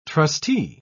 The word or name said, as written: trustee